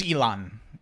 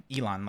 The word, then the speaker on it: Ilan: It's Elon. Elon, like the guy who makes Tesla, but I'm not a giant asshole.